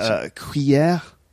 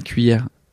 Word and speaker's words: cuillère: We just don't use cuillère? – Yeah, cuillère. – But there's a vowel sound in there that I can't do. – Cuillère.